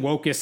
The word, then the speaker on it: wokists: What Islamists and wokists have in common? – Wokists. Okay. – Rhymes with locusts.